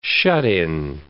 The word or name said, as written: shut-in